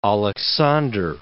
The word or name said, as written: Aleksandr